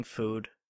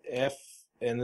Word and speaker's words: pho: It's like F and then a schwa. How do you say a schwa? Hold on. Oh, no, it's fa. – It's fa? Right. Yeah. Okay, that's what I thought. I said fo for the longest time.